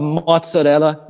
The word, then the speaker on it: mozzarella: Love mozzarella. Mozzarella. Is that how you say it if you're Italian? – Yeah.